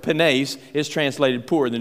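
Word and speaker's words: penes (πένης): The first word is penes, P-E-N-E-S, and that second E is a long E. It's pronounced penes.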